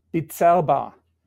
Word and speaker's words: Bizerba: How did you make the leap from Birzeba, and I'm sure I'm pronouncing it wrong, to Miele. – So the exact pronunciation is Bizerba, Bizerba.